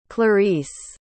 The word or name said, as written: clarisse